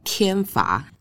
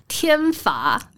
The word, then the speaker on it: 靛花: 哦，其实我的客语也没有很好，不过电花的客语是天罚，天罚。